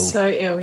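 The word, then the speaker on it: SO – IL: Is it pronounced Soil? I've always in my head pronounced it So Ill. – So Ill, yeah. – So yeah. Ill, the, I'm I've been saying Soil. – Like, yeah, I've heard others say Soil.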